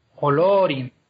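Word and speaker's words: Olórin: Y luego, por ejemplo, el nombre que tenía en Valinor eh, Gandalf, Olorin, que lleva tilde en la O, esa tilde lo que marca es que la vocal es larga, Olorin. Entonces se lleva el acento también.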